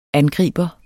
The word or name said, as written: angriber